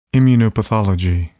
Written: immunopathology

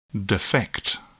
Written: defect

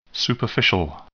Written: superficial

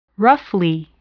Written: roughly

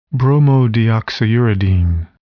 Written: bromodeoxyuridine

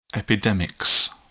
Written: epidemics

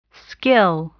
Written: skill

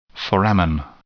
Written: foramen

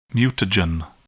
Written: mutagen

